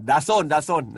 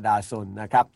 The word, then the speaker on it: DAZN: ดาโซนดาโซนนะฮะอ่านว่าดาโซนนะครับก็เป็นสื่อที่เข้าถึงเรื่องของกีฬานะฮะดาโซนนะครับ